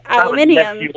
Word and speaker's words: aluminium: We do spell it differently in the States, therefore different pronunciation. There was no extra I at the end, therefore aluminum. Whereas here there is the extra I, so it's aluminium. The same is... – How do you spell yogurt? – Y-O-G-U-R-T, so it is spelled differently and pronounced differently.